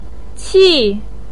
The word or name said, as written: qi4